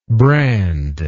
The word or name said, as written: brandless